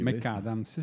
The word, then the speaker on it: McAdams: McAdams.